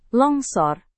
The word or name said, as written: longsor